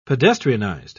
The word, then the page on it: pedestrianized: British English